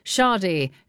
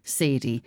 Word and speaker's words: Sade: And one of them was Sade, not pronounced Sadie.